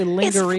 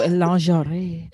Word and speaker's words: lingerie: You know, people say lingerie. – It's French, girl. – It's French, so lingerie.